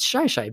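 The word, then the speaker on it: Shi Shi: It's S-H-I-S-H-I. I've originally pronounced it as Shishi. I've just in my mind that made more sense, but then somebody from Washington corrected me and said it's Shy Shy Beach.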